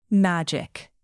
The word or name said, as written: magic